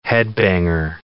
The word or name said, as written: headbanger